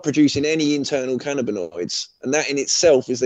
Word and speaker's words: cannabinoids: It is cannabinoids.